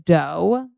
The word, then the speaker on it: Deux: So this is Doux. That's spelled D-E-U-X. Okay. Like dough, like cookie dough, like dough.